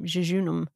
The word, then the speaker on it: jejunum: I like jejunum. Jejunum. Yeah. How do you pronounce it? – Jejunum. That sounds right to me.